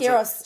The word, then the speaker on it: Skyros: Is that how you pronounce Skyros.